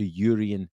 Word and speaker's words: Jurrien: Jurrien Timber, after he confirmed that you should pronounce the J.